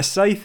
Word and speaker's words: scythe: A scythe? – Is that how you pronounce that? – Yeah. – I've never heard someone say it out loud. – A scythe is also used.